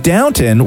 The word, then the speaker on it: Downton: And it was the same thing with Downton, where I just thought that was like an accent thing where they don't say downtown. – They don't pronounce the W. – No, yeah. – Or the second W.